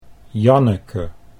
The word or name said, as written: Janneke